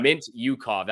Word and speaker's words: Mintyukov: Yeah, Mintyukov. That's how you pronounce it.